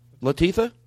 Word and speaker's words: Latifah: I will say I was watching, what's Queen Latifah? – Yes. – I'm saying it wrong. Queen what? – Latifah. – Latifah. Yeah. I said it wrong.